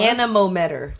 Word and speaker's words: anemometer: Anemometer measure. What? Anemometer.